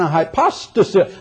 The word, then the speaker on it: hypostasis: Oh, God is an hypostasis. I can't even pronounce that word, can I? Yeah, try it again. Hypostasis. That's it. You got it.